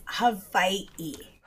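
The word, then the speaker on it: Hawaii (Hawaiʻi): The proper pronunciation. – Here we go. Erin, write that down. – Is Hawaii. – Hawaii. With like a little apostrophe between the I's? – It's not an apostrophe. It's an okina.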